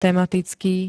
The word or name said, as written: tematicky